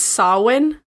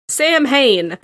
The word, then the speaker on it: Samhain: It's pretty dark, but this idea would eventually become connected to the Celtic festival of Samhain. I believe is how you pronounced it. – I think it's Samhain. Yeah. – Yeah. One of the precursors to our modern day Halloween. – Samhain.